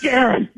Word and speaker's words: Guerin: It's Guerin. G-U-E-R-I-N.